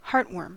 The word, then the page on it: heartworm: American English